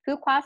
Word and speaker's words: Fuqua: He's also professor at Duke's uh, Fuqua. Did I say that right? Fuqua School. That's correct